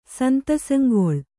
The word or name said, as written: ಸಂತಸಂಗೊಳ್